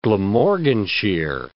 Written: Glamorganshire

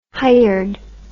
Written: hired

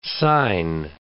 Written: sign

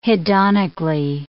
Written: hedonically

